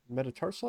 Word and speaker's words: metatarsal: Fifth metatarsal, I believe. Am I saying that right? – Yeah.